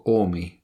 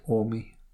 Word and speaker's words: Qormi: It's called Ormi, but it's spelled Q-O-R-M-I. – Mm-hmm. – But you don't pronounce the Q's, so it's just Ormi.